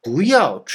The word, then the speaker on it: 不要: 例えば、不要出去という時に、外行くなと、出てくなということです。発音ではですね、ヤオが第 4 世ですから、第 2 世に成長変化しますね。